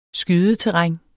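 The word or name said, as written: skydeterræn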